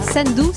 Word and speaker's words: Sendou: Sendou, c'est comme ça qu'on prononce ? – Oui, qu'est-ce que ça veut dire ? – Sendou.